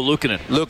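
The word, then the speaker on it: Luukkonen: How do you say his name again? Luca, the goalie. – Oh, Lukanen. – Lukanen made a nice save on that one, but it was from Henrik from behind the net.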